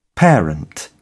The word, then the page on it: parent: British English